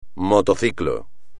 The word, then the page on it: motociclo: espagnol